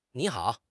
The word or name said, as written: ni3 hao3